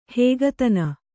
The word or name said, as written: ಹಗೆತನ